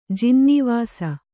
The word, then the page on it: ಜಿನ್ನೀವಾಸ: Kannada